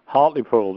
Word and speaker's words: Hartlepool: Is it Hartlepool? – Hartlepool, that's right, yeah. – And I called it Hartlepool.